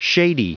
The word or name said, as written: shady